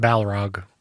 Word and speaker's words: Balrog: It's sort of, I think the Mines of Moria, I think the Balrog. – It's pronounced Balrog.